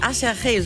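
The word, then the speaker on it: Aserejé: Las Ketchup Song von einer Combo namens Acherche, so wird die ausgesprochen, aber sieht aus wie Acherje, so kann man sich das auf jeden Fall gut merken.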